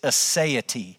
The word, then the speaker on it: aseity: The theological term that we would actually use for God's self-existence is aseity.